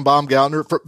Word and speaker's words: Baumgartner: Hi, it's Brian Baumgartner.